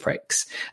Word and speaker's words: Prix: Definitely pronounced that way as well. It's spelled P-R-I-X, but we do pronounce it Pricks.